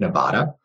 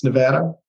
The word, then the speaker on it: Nevada: At one point, we had a conversation about the Silver State, Nevada, because I think some people pronounce it Nevada. But if you work in politics or you've been to that state, you know that it's Nevada.